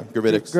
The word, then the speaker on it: Gravitics: And I feel like Gravitics is, am I saying it right? – Oh, yeah, Gravitics.